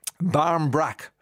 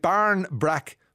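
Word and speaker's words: barmbrack: And Amy Huberman, finding joy, fame, has been on Instagram, and she's been mispronouncing Barm Brack forever. Says, spend most of today walking around in total confusion, guys. I've been calling it Barn Brack my entire life. It's Barm. Barm.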